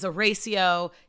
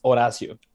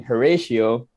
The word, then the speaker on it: Horacio: So my name is pronounced Horacio. So Horacio. In Spanish, the H is actually silent. Mm-hmm. So that's why everybody's like saying Horatio.